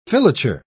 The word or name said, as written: filature